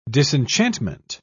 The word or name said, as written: disenchantment